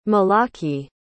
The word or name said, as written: malaki